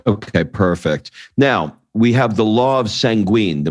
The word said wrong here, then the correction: jure sanguinis: Am I saying that right? – Jure sanguinis. The law of the blood.